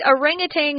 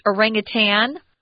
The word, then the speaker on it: orangutan: Notice that orangutan is sometimes pronounced orangutan. It's very close. I'm sure some of you think, isn't she saying that the same way? But there is a little difference. Orangutan, orangutan, they're both correct.